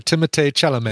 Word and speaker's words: Timothée Chalamet: Yeah, not mispronounced at all, by the way. That's exactly right. Timothée Chalamet.